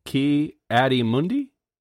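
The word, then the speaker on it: Ki-Adi-Mundi: Paik Allen says, I'm going to butcher this. Ki Adi Mundi? Is that right? That's right, yeah.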